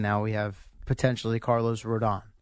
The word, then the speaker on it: Rodon: Rodon. – Rodon? – Yes.